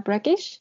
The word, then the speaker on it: Breakish: Ok, entonces Yuji eh, viene de una comunidad en el sur de la isla de Skye que se llama Breakish.